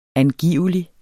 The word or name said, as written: angivelig